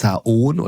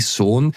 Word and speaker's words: Saône: Ist es Saône oder nein, es ist die Saône.